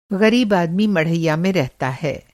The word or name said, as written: मढ़ैया